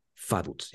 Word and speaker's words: Vaduz: Esa V chica se pronuncia como F y el corredor de autos Vettel, no es Vettel. Eh, y la Z, pues es una TS. Entonces, su pronunciación es Faduts, ya lo saben ahora.